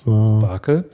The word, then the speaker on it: SPARQL: S-P-A-R-Q-L. – Das mit QL hatte ich nicht. So, jetzt gucken wir mal. – Spricht sich aber genau wie das Funkeln. – Ja, jetzt habe ich es auch gefunden. Ja, genau. Das war der entscheidende Punkt. Ja. Okay, ja. – Das ist Sparkle.